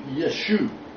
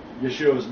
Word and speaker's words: Yeshu: The Galilean pronunciation of the Messiah's name being Yeshu. Okay? The Galileans did not pronounce Yeshua's name as Yeshua. The A was dropped. It was Yeshu.